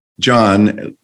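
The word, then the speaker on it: Jon: So it's J-O-N, John Leaf, L-I-E-F-F. J-O-N, no one knew John until John Stewart, I think, and then everything was always had an H.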